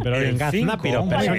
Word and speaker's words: gaznápiro: ¿Gaznapiro? – ¡Gaznapiro! – ¡Gaznapiro! Con la tilde la, pero bien. Gaznápiro, persona simple, crédula o que se comporta con torpeza.